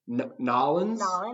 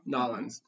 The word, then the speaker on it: New Orleans: I believe they pronounce the locals say Nollins. Nollins.